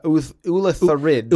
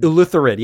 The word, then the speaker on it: Ulitharid: You want an illithard. Or no, I'm sorry, an Ulithard. – Ulithard, yeah. – Ulitharid. – Ulitharid, yes.